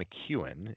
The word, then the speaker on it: McKeon: One guy who's looked, I would say, who surprised me a little bit is Sean McEwen. It's spelled McKeon, but he's the Michigan State tight end, Michigan tight end.